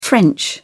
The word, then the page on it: french: British English